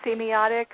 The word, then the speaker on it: semiotics: And so that's right up your alley with your study of, is it pronounced semiotics? – Semiotics, yeah.